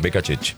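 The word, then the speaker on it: Beccacece: Está hablando Becacheche, así se pronuncia, Becacheche.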